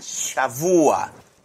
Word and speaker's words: shavua: Semana Shavua. S-H-A-V-U-A. Acento en la U. Shavua.